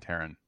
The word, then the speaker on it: Taran: I don't actually know how Lloyd Alexander pronounced it, but it's always been Taran.